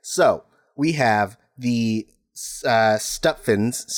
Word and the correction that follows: Sutphin: Stuffins? Uh, I can't pronounce Sutphin. Sutphin. We have the Sutphins.